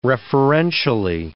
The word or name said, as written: referentially